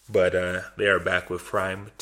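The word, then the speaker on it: PRhyme: It's Prime. They want it pronounced Prime. – Okay. DJ Premier. – But it's capital PR. Like, yeah. So it looks like P-Rhyme.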